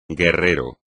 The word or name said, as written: guerrero